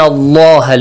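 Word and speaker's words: Allah (الله): Inna Allah, look, the word Allah is pronounced with a heavy sound.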